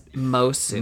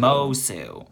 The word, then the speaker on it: Moço: It's Miss bad. Moço. Moço. Moço, yes, and it means in in Portuguese, because she's from Lisboa.